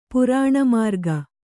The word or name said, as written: ಪುರಾಣಮಾರ್ಗ